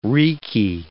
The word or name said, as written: reeky